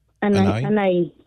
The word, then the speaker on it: Anai: Let's go to Anahi. I hope I'm saying it right, in Long Beach. Or Anai. Am I saying Anai right? – Good morning. Anai. – Anai, I'm sorry.